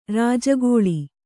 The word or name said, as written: ರಾಜಗೋಳಿ